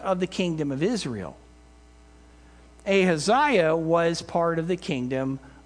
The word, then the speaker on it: Ahaziah: The problem is that in his zeal, Jehu also attacked the family of Achaliah. I'm not saying that right. Hold on. That's one of those biblical names. It's like, what was that again? Okay, Ahaziah. There we go.